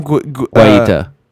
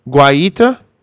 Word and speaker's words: Guaita: Guaita. – Guaita.